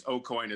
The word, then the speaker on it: AuCoin: So it's AuCoin as if it were Irish. O apostrophe coin.